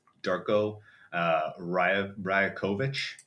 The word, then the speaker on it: Darko Rajakovic: The other one I want to kind of bring up, because we already talked about the potential positives to the good, Darko Rajakovic.